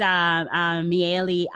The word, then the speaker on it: Mayealie: So our guest today is Mayealie Adams.